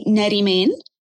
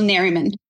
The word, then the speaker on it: Nerimen: So there's actually two pronunciations. So if you want to pronounce it like an Arab, you're going to say Nerimen. – Nerimen. Uh-huh. – Nerimen. Okay. And if you want to pronounce it like how it's going to be easier for you to pronounce it, just say it if like Superman was like someone's last name. So like Bill Superman. So it's going to be Bill Nerimen.